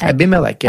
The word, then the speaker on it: Abimelech: Abimelech, yeah.